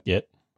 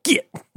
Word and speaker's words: Git: Have you heard the good news about Git? – Git. You sound like you're talking like me when I say get, and you say Git. – That's how you pronounce it, right? – Yeah.